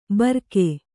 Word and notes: ಬರ್ಕೆ: barke

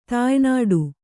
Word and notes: ತಾಯ್ನಾಡು: Kannada

